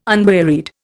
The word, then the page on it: unwearied: Indian